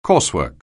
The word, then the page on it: coursework: anglais